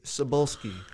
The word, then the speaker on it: Cebulski: CB Cebulski. I hope I'm saying that correctly. – Yeah.